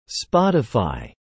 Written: Spotify

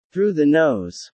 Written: through the nose

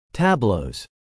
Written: tableaux

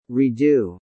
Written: Redo

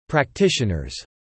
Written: practitioners

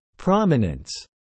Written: prominence

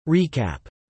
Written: recap

